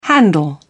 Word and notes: handle: British English

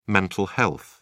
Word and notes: mental health: British English

